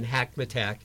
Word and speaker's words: Hackamatack: And I would just add to that sometimes people get caught up in pronouncing Hackamatack, but let me just tell your listeners that it rhymes with Big Mac Attack.